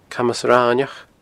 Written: Camas Raineach

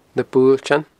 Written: nabuailtean

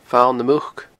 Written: Fal nam Muc

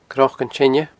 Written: cnocanteine

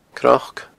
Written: cnoc

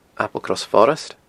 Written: Applecross Forest

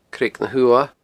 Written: Creag na h-Uamha

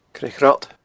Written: Creag Ghrod

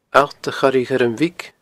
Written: Allt a Choire Ghuirm Bhig

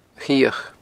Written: A Chioch

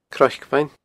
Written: Croic Bheinn

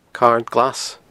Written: Carn Glas

